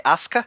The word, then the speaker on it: Asuka: Porque não é açúcar, é Asuka.